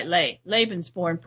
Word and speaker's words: Lebensborn: I don't know if it's pronounced Lebensborn or Lebensborn. – Lebensborn. – Program. – Lebensborn. Yeah. – Leben, that's right, Lebensborn program.